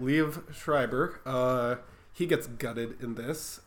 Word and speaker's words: Liev Schreiber: Liev Schreiber? – Yes.